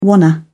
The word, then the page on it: wanna: British English